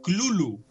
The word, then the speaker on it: Cthulhu: A Lovecraft le preguntan cómo se pronuncia y él dice que no hay una pronunciación oficial porque está hecha para es una palabra hecha para gargantas no humanas. – Eso. suena algo así como Clulu.